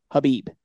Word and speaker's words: Khabib: So I know the K's there, but I believe it's pronounced Khabib.